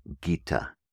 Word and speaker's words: Gita: Bhagavad is spelled B-H-A-G-A-V-A-D. Bhagavad. B-H-A-G-A-V-A-D. Bhagavad. Second word, Gita. G-I-T-A.